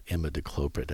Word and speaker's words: imidacloprid: For mealybug, what I recommended was an active ingredient called imidacloprid.